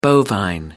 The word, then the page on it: bovine: American English